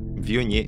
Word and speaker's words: Viognier: Viognier, yeah, that's Viognier.